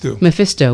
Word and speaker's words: Mephisto: How do you pronounce Mephisto? – Mephisto.